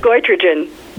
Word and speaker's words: goitrogen: I'm afraid to pronounce the word. How do you say it? – Goitrogen. – Goitrogen is a food that depletes thyroid function, and asparagus tops the list because we're going alphabetically on this particular list.